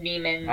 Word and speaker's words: daemon: So there's the word demon, like D-E-M-O-N. – Yeah. – And that's used in here. But there's also D-A-E-M-O-N. Is that Daemon? – I believe that's still demon.